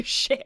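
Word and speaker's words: Schitt: The second I started watching it, I was like, how are they allowed to say shit? – Because it's spelled S-C-H. – Doesn't matter. Like, they're still saying shit.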